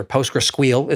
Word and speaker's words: PostgreSQL: What differentiates time series from, let's just start with the easy one, something like MySQL or Postgres, or PostgreSQL is how I insist on pronouncing that one.